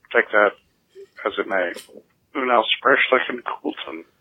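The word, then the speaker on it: Unaussprechlichen: I pronounced the name of von Juntz's nefarious tome Unausbrechlichen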